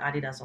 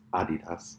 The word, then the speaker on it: Adidas: Adidas. – Adidas. – You gotta say it like American. – No, it's not Adidas. – American. Adidas. Is German-based. Herzogenaurach. It's called Adidas.